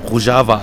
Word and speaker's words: Rojava: Rojava, also so wird es ausgesprochen, habe ich wieder was dazugelernt.